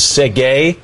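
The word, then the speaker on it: Tsegaye: You were trying to pronounce the name of the winner of that marathon, which is Segay.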